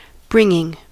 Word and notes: bringing: American English; /ˈbɹɪŋ.ɪŋ/

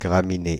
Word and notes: graminée: Paris; /ɡʁa.mi.ne/

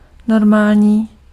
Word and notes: regular: /ˈɹɛɡ.jə.lɚ/

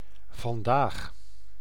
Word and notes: today: /tə.ˈdeɪ/

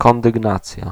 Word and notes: verdieping: [vǝɾ.diː.pɪŋ]; /vǝɾ.di:.pɪŋ/

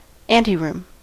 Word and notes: antichambre: France; [ɑ̃.ti.ʃɑ̃bʁ]

